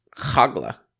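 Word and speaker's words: Hoglah: Interestingly enough, looks like the Hebrew pronunciation is Hogla. – Even better. – Yeah, a little Middle Eastern action. – Yeah. – Hogla. – That back of the throat action. – Hogla. Hogla. A little KH action.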